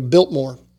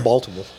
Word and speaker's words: Biltmore: He was talking about his family loves to go to Biltmore in Asheville. It's not Baltimore. – Yeah, Biltmore. I was going to say, I think it's pronounced Baltimore.